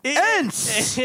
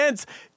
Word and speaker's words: Ense: E-N-S-E is how you spell it. – A new chant. E-N-S-E. Ents. – E-N-S-E. Ents.